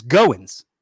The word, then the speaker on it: Goins: My real last name is Goins, right? Which is G-O-I-N-S. But it looks to people like coins. So they pronounce, oh, like Goins? It's Goins, right? And I'm like, no.